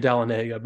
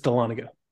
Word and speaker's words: Dahlonega: That said, I had to learn how to say this too because I'm not local. So we went to Dahlonega. I kept saying Dahlonega, but it's Dahlonega. It was apparently the nation's first major gold rush.